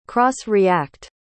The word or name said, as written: cross-react